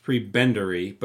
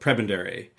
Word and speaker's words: prebendary: I think he's an Anglican bishop, or it's called a prebendary, which looks like it looks like it should be pronounced prebendary, but it's called prebendary.